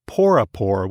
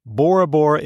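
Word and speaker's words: Porapora: It's actually pronounced Porapor with a P in the Tahitian language. They don't have the letter B in the Tahitian alphabet. Bora Bora is the French way of pronouncing the island name.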